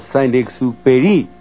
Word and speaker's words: Saint-Exupéry: Él es el francés Saint-Exupéry, o Saint-Exupéry, más propiamente pronunciado.